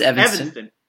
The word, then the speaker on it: Evanston: Is that how you say good job. Yep, that's how you pronounce Evanston.